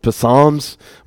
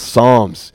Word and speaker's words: Psalms: It starts with a P. It's a little tricky. I used to pronounce it Psalms, but it's pronounced Psalms.